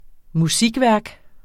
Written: musikværk

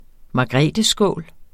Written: margretheskål

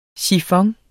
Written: sifon

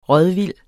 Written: rådvild

